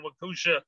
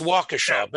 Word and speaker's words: Waukesha: In the Christmas parade in Waukesha, Wisconsin. – Yeah, it's Waukesha, but it's all right.